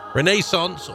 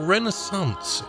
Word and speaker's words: Renaissance: Renaissance or Renaissance is how you can pronounce it either way if you want.